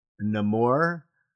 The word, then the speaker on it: Namor: Namor, the submariner. – That's right. – Namor, the submariner.